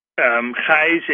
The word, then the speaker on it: Gijs: Heise is spelled G-I-J-S. And in my mother language, I'm from Holland, the Netherlands. The combination IJ is actually a Y. So you could see it as GYS.